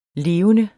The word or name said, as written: levende